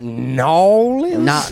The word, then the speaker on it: New Orleans: I believe it's pronounced Nolens.